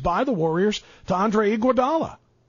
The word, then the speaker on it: Iguodala: It's Andre Iguodala. G-U, gua.